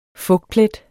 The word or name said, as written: fugtplet